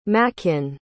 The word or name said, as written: makin